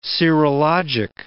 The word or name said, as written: serologic